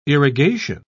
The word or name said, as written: irrigation